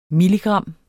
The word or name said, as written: milligram